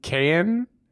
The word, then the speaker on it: Keoghan: Keoghan I, I, think you don't pronounce the G.